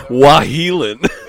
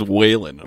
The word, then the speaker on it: Wahelin: Mine's like simple, but no one knows how to say it. Wahelin. Everyone calls it says Whalen. I'm like, no.